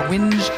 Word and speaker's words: whinge: W-I-N-G-E. – Is? Oh, you forgot that silent H. W-H-I-N-G-E. – Oh. – Whinge. – Oh. Whinge. – So it's just like hinge with a W in the front.